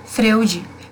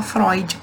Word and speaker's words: Freud: Que é um psicanalista muito conhecido, e eu li Freud, porque a escrita se escreve Freud, mas a gente fala Freud, porque é alemão.